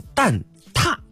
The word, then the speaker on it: 蛋挞: 刚刚收到姐姐的提醒啊，说这个字啊它不能念塔，它得念四声啊，念踏啊。对，这个呢在那个普通话的测试当中，如果出现了这个字儿啊，我们要读成蛋踏啊。但是呢好像大家一般在聊天的过程当中说的都是三声会比较多啊。